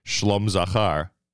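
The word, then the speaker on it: Shalom Zachar: Remember, Mayor Weingarten always taught us that the proper pronunciation is Shlom Zachar.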